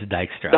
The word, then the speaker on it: Dijkstra: Is it Dijkstra? – Yeah, it's okay. Dijkstra. Is that right? Is that the correct pronunciation? I'm horrible with pronunciations. But it's Dijkstra.